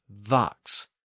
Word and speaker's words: fox: Now, in prior episodes, I've mentioned that in the far south of England, people often pronounced the initial F sound as a V. So fox was pronounced as vox, and that's how we got the word vixen, meaning a female fox.